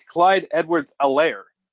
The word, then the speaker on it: Edwards-Helaire: I've been calling him Clyde Edwards-Helaire. I guess the H is silent. It's Clyde Edwards-Helaire.